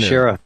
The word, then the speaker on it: Shiira: I don't know how to pronounce it, but it's Shiira. It's a new Shira. – Browser. – Yeah. It's not that new. Shira.